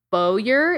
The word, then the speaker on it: Bowyer: But this is a man named, I believe his last name is pronounced Bowyer. It's like B-O-W-Y-E-R, Bowyer.